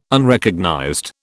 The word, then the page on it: Unrecognized: British English